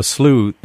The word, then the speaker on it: slough: And I should say, to those who don't know, a slough in this case is spelled S-L-O-U-G-H, which is like the word slough.